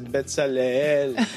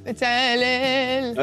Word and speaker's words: Betsalel: Non, c'est Betsalel. – Ouais, peut-être que ça se prononce différemment pour de vrai. Hein. – Faut bien ressentir le E. – Betsalel.